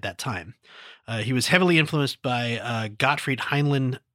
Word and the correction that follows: Helnwein: Helnwein. I can't ever pronounce that. Gottfried Helnwein.